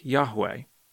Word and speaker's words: Yahweh: More modern scholars think it was probably pronounced more like Yahweh.